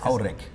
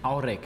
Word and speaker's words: Aurich: Äh, wie wird denn Aurich überhaupt auf Ostfriesisch ausgesprochen? Ich habe mir das Aurek. – Aurek. Aurek, okay.